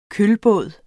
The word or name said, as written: kølbåd